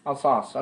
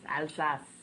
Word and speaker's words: Alsace: Alsace, okay. No, say it right. – Alsace. It's somewhere in between. Between an A and an ah. It's Alsace.